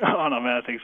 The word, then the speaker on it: onomastic: Did I get that right? Is that how you pronounce onomastic? – Onomastic. So it's a big mouthful.